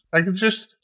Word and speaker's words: Packagist: Packagist have come up with a solution, haven't they? – Yes, they have.